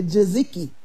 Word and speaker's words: tzatziki: We call it tzatziki, but it's really tzatziki. Tzatziki is how it's pronounced.